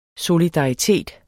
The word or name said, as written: solidaritet